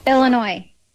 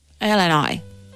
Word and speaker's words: Illinois: Él, español, ella estadounidense, concretamente de Illinois, y fue quien grabó este audio para que escuchemos cómo se dice realmente. – Aquí te doy la vuestra. – Illinois. – Vamos a poner la parte corta. – Illinois.